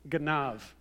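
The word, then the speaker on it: ganav (גנב): In English, it ends with the letter B, but in Hebrew, it's pronounced ganav.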